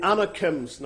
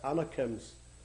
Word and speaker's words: Anakim: Now, you'll have heard me say Anakim, and you've been thinking to yourself, why doesn't he pronounce the S, Anakims? Because I don't speak about one man and two men's. Anakim is the plural reference.